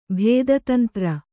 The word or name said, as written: ಭೇದತಂತ್ರ